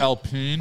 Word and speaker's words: Alpine: It's pronounced right? – Alpine. – Alpine.